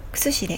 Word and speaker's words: 薬師: くすしである薬師」と書いてね「くすし」と読む